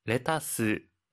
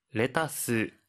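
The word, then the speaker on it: レタス: 하이, 양상추와 양배추는 레타스, 캬베츠와 같이 영어를 일본어식 발음으로 표현하니 주의해 주세요.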